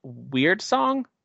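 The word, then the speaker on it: Wyrdsong: Weird song, right? What? – Yeah, weird song. – Yeah, weird song. – But it's a WY, not the word weird.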